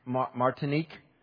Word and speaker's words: Martinique: Because they went to Martinique.